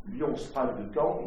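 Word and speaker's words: Lyon Sprague de Camp: Alors je prononce son nom à l'anglaise, mais Lion Sprague de Camp, il avait des ancêtres français,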